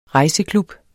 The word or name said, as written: rejseklub